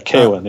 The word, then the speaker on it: Kaylin: Kaylin, am I pronouncing that right? That just doesn't seem like a name to me. – Yeah, Kaylin. Yeah.